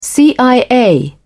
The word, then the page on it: cia: British English